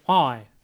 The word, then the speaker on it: Huawei: Sag doch bitte Huawei. – Ich habe gehört, das wird gar nicht so ausgesprochen, nicht mal annähernd. – Doch. Huawei.